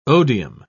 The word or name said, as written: odium